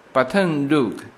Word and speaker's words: Baton Rouge: 我到目前为止哈，呃，当然那种小城市，比如说今天，其实我昨天就已经进到了路易斯安那，然后呢，昨天晚上是住在这个路易斯安那州的首府，叫做 Baton Rouge，呃，这个发音有是法文的发音哈，中文有的把它翻译成巴吞鲁鲁日啊，或者说巴顿鲁治啊，这个大概就是这个首府哈。